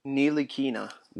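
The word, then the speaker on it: Ntilikina: How do you say it again? – Neelikina. – Neelikina. – So Neelikina. No T, right.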